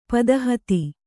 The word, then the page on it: ಪದಹತಿ: Kannada